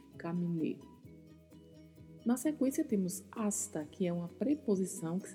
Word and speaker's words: hasta: Na pronúncia, a letra H, a letra H não tem som muito cuidado para não usar na pronúncia como se houvesse um R e falar rasta.